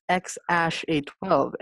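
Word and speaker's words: X Æ A-12: What he says it's pronounced as, it's X Ash A12, and says that his partner was the Arch Angel 12.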